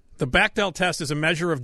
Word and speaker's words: Bechdel: Bechdel. – Bechdel.